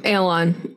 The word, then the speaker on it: Aeluon: He's an A-E-L-U-O-N. Aeolon? – Yeah, Aeolon. Aeolon. Aeolon is how they say it in the...